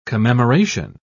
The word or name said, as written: commemoration